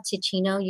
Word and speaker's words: Teeccino: And so I love Ticino. You can find them at your local store, but that's spelled T-E-E-C-C-I-N-O, and that's no caffeine, but it's herbal, it's full of mushrooms, antioxidants, and also it tastes like coffee or a strong tea.